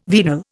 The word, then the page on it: venal: Indian